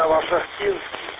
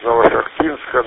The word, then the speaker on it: Новошахтинск: Превосходно принимаю вас из Новошахтинска, но я почему-то всегда считал ударение Новошахтинск. Но если Новошахтинск, так тому и быть.